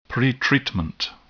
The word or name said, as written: pretreatment